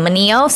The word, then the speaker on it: Mineo: I want you guys to take a listen to Andy Manio, I think that's how you pronounce his name, Manio. So it's Andy, A-N-D-Y, and then M-I-N-E-O.